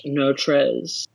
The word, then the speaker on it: Notrees: He's from a place in Texas which is spelt N-O-T-R-E-E-S, no trees, but it is pronounced Notres.